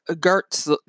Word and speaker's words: Goertzel: I'm going to actually just spell it for, and I said Goetz, but it's Goetzel. So it's Dr. Ben Goetzel, G-O-E-R-T-Z-E-L, Ben Goetzel.